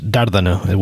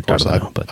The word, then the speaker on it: Dardano: Dardano, who was... – I think it's Dardano with the inflection in the first syllable there, Dardano.